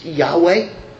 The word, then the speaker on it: Yahweh: They never even uttered the name of God, which really is Yahweh, as it's supposed to be pronounced in the Hebrew, or Jehovah.